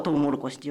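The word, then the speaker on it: 玉蜀黍: 3 文字を書くとトウモロコシって読むんで、ちょっとラジオではお伝えしづらいんですけれども、このトウモロコシという名前は異国から伝わったモロコシに似た食物に由来します。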